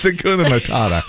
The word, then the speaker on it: Hakuna Matata: Hakana Matuta. Whoops. – What was that? That's what the warthog does when he's had too many beans. – It's Hakuna Matata.